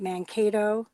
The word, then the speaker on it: Mankato: And even just learning about things like what happened, and correct me if I'm wrong, you know, the massacre at Mankato, am I saying it right? – Yep.